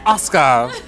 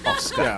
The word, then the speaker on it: Asuka: And I was like, no, it's Asuka. – Asuka. Yeah. – The U is silent.